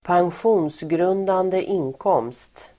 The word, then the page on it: pensionsgrundande inkomst: ²pangsj'o:nsgrun:dande ²'in:kåm:st el. pen-